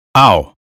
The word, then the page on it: mouth: American English